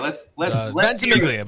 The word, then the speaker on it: Ventimiglia: Ventimiglia, but you can just call me Bob.